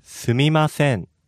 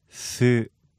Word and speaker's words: Sumimasen: Sumimasen. – Excuse me. Can you break that down by the syllables? – Sumimasen. Sumimasen.